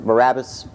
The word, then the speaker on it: Barabbas: Did I say it right this time? Barabbas? Thank you.